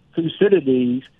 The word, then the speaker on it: Thucydides: Well, what's interesting is Thucydides.